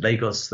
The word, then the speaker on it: Lagos: So, you mentioned Lagos, that's how I pronounce it. Lagos, the former capital city of Nigeria, now the business city, the commercial center, financial center, massive sprawling city.